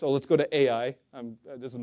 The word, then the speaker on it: Ai: Literally, it's spelled Ai, and that's how it's pronounced too. Ai.